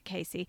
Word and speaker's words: Casie: And poor old Casey, it's spelt C-A-S-I-E, but I think she pronounces it Casey.